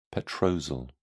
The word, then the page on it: petrosal: British English